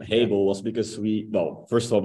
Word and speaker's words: Hable: And if we pronounce it like Hable, it has able in there because we enable people to use technology.